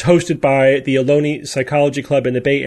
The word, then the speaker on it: Ohlone: Rhymes with baloney, I'm told, Ohlone.